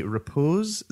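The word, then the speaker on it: repoussé: And the copper plates themselves were made in this style called the repose. Is that am I saying it correctly? – Sure.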